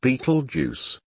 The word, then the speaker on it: Betelgeuse: The star in question is Betelgeuse, pronounced Beetlejuice.